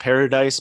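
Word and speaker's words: Páradais: And it is, it's, I think it's pronounced Paradise, but it's like P-A-R-A-D-A-I-S because it's a Spanish spelling.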